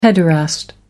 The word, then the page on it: pederast: American English